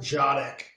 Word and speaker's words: Dziadyk: It's pronounced Jodik, DZIADYK.